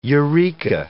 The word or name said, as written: eureka